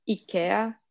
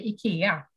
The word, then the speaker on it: IKEA: What is the correct way to pronounce IKEA, IKEA, or IKEA? Yes, um, uh, in Swedish uh, we say IKEA and uh, of course in english it's uh, more ikea